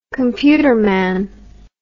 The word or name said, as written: computerman